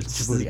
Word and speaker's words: Djibouti: I had no idea where it was because it's spelled like D H I J U T or something. – Right. – But it was phonetically on the internet, so I could see it was Djibouti.